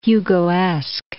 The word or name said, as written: Hugoesque